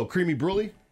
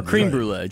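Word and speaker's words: crème brûlée: But aren't you saying it wrong? – Oh, creamy brulee? – There you go. – Sorry. – I love creme brulee. – Or cream brulee, just the cream part.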